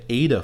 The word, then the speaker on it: AIDA: This is a formula that's existed almost as long as advertising has, and it's called the AIDA formula. You spell that A-I-D-A.